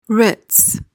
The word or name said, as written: ritz